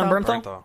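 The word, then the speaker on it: Bernthal: No, because we've seen all these pictures with I'm gonna butcher his name, John Blumenthal. Did I say Berntal. – It? – Berntal. John Berntal.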